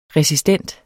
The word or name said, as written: resistent